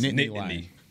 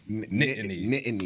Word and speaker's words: Nittany: The way I do it is I just break the syllable. I just put a space right there. So it's Nittany. – Nittany. – That's Nittany.